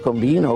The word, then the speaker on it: Combin: venne clamorosamente rovesciato da tre gol di Nestor Combino o Combin, per chi lo pronunciava alla francese, e tre gol bellissimi, tre gol di possesso, tre gol furiosi.